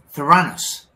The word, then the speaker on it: Theranos: Fail of the week this week, Elizabeth Holmes how do you pronounce the company name? – Theranos.